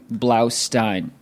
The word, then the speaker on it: Blaustein: Blaustein.